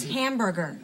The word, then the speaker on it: hamburger: That's hamburger. The H is silent.